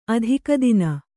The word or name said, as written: ಅತ್ತಿಗೆತನ